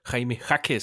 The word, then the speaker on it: Jaime Jaquez: valget Jaime Jaquez, som jeg primært ikke taler om, fordi jeg ikke kan finde ud af at udtale hans navn.